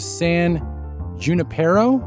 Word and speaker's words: San Junipero: Number two is San Junipero.